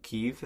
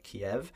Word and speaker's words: Kyiv: It's actually going to Kyiv in Ukraine, or as Americans like to pronounce it, Kiev. – I did not know that it was actually pronounced Kyiv. I had no idea, dude. I was because when you said Kyiv, I didn't know that was the proper pronunciation.